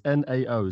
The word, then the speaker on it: NAO: How do you pronounce her name? It's N A O. Is it now? – Now, isn't it? Or uh, Nao? No, she, I don't know. Nao.